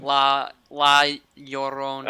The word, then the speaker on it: La Llorona: And I'm glad you pronounce it wrong too, because I never get it right. – Am I pronouncing it wrong? I wouldn't. – La Llorona. – Llorona? Okay.